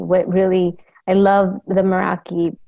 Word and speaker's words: Meraki: Is it Meraki? Is that how you say it? – It's Meraki by Luisa.